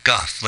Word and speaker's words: Gough: Well, they mispronounce this as Go Houston, but they're actually known as Gough Houston. – That's good. – Yeah. – That is really good. Well, Go Houston is Gough. How do you say it in San Francisco? – Goff, like you're gonna cough.